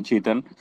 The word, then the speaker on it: Chetan: If you can pronounce my name properly, you can call me Chetan. A lot of people call me Shaitan, Satan, with a different names.